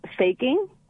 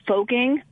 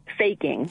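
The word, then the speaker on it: Foecking: Faking. – Faking. So it's an A sound, even though it's spelled O-E.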